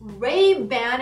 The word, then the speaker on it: Ray-Ban: Ou I don't know why I still don't have a Ray-Ban Ray-Ban é a forma que a gente pronunciaria em inglês.